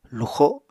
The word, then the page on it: luxo: Apatani